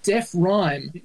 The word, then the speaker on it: Defryme: We've got Kate Sobrano, Margaret Ehrlich, Joe Cocker, Tom Berlinson, Darren Hinch, and some obscure Australian band called Deaf Rhyme, which, again, it's got my pedantry nerves tingling because it's spelled D-E-F-R-Y-M-E.